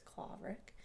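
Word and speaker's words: Claverack: I live in a town called Claverick, which is mispronounced quite a bit, but growing up here, we've always said it with a little bit more throat, so it's Claverick. It's a Dutch word.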